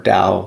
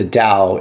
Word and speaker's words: Tao: So Taoism, the first thing I want to say is, like, sometimes you'll see the word Tao spelled with a T, sometimes with a D. It's when it's translated into English, it has different transliterations, but it's typically pronounced like with a D sound.